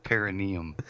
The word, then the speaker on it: Perineum: Perineum.